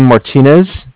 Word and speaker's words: Martinez: It's J-U-L-I-A-N. Last name Martinez. M-A-R-T-I-N-E-Z. And I'm calling from Bank of Cardiff. – Okay. – Cardiff is spelled C-A-R, D as in David, I-F-F as in Frank.